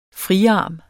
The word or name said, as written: friarm